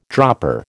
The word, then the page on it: dropper: American English